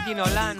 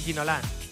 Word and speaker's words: Ginola: Mira, nos decía un oyente, Cantona o Ginola no tenían acento y se acentuaban en la última sílaba.